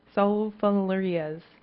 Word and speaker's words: sulfonylureas: Does anyone know how to pronounce this correctly? Sulfonylureas.